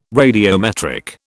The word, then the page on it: radiometric: British English